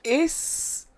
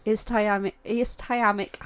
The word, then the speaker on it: ischemic: Now, this is when pronunciation gets interesting. Ischemic heart disease. – Ischemic heart disease. – It's got a CH in it.